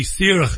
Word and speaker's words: Saoradh: SAORADH is the way it's pronounced, it's freedom.